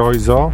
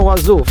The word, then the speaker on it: Oizo: Czy to jest Mr. Oizo? – Czy Łazu? – Czy Łazu? – Podobno Łazu. Flatbeat nazywa się ten numer i faktycznie dwójka energetyczna jak piorun w ogóle.